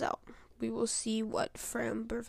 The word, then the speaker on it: Framber: Starting for the Astros will be Framber Valdez. Now, I used to mispronounce his name, Frambler. I will no longer do that, but he has had two rough starts this postseason.